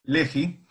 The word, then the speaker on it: Lehi: Y después tenías los que estaban ya más completamente corridos a cualquier otro lado, que era el Lehi, o Lehi, no sé si la pronunciación Lehi. Es Lehi, los luchadores por la libertad de Israel, que esos, mientras leía un poco cuando...